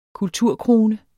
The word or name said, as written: kulturkrone